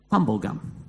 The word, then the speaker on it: Tumbulgum: And there's a little town spelt T-U-M-B-U-L-G-U-M. How would you say that? Tumblegum. We called it Tumblegum for years.